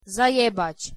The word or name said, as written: zajebac